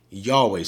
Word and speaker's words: Yahweh: Yod-Heh-Wow-Heh, Yahweh. Some people pronounce it Yahweh.